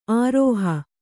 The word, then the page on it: ಆರೋಹ: ārōha